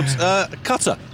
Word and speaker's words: Qatar: You're from a country called Qatar. But also, how do you pronounce it? I got told Qatar. Yeah, is the correct pronunciation.